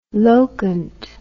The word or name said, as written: locant